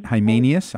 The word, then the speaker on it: Hymenaeus: So with 1 Timothy, I can't pronounce his name correctly, but I think it's Hymenaeus. – Hymenaeus, uh-huh.